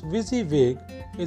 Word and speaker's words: WYSIWYG: WYSIWYG, pronounced WYSIWYG,